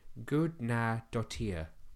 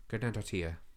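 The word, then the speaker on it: Guðnadóttir: It's a very difficult surname to pronounce. Look, I'm going to show you it on my phone. So it's Gudnadottir. Gudnadottir.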